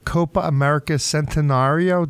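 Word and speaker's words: Copa América Centenario: Now the U.S.-based, I'm probably going to mispronounce this, the Copa America Centenario. Did I say that correctly? – You said it correctly.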